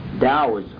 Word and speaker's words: Taoism: Now Taoism is spelled T-A-O-I-S-M. T-A-O-I-S-M is a Chinese religion, and it's pronounced Taoism. Taoism. Now here's the history of Taoism. Lao Tzu, which is L-A-O hyphen T-Z-U, Lao Tzu was supposedly born between 604 and 570 B.C.